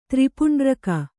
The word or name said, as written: ತ್ರಿಪುಂಡ್ರಕ